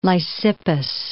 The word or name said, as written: Lysippus